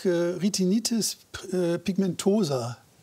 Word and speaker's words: Retinitis pigmentosa: So, jetzt nächste Erkrankung, Retinitis pigmentosa. Habe ich das richtig ausgesprochen? – Ja.